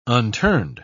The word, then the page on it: unturned: British English